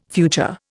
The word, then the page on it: future: Indian